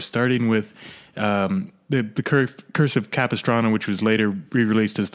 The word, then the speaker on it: Capistrano: I think it's Capistrano. – Capistrano. – The Curse of Capistrano.